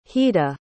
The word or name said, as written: hida